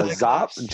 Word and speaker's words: Czop: Oh, that's Zop, John Zop. – Zop. So the C in front of his, because his surname is C-Z-O-P.